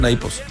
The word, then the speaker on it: Naples: Sí, se pronuncia Naipos.